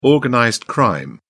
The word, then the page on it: organized crime: British English